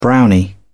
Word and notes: brownie: British English

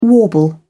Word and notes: warble: British English

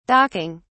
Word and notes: docking: British English

